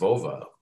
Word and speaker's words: Vova: All right, moving on to for the last introduction is Vova Ivanov. Am I saying that right, Vova? – Yeah, exactly. Almost like Volvo, you know.